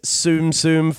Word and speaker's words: Tsum Tsum: I believe it's pronounced Disney's Tsum Tsum Festival.